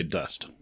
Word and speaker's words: dost: It's D-O-S-T. – It is spelled D-O-S-T, but you'd say dust.